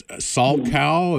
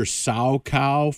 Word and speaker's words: Salchow: Is Salchow? Yeah. Is that how you pronounce it? I think you don't sound the H.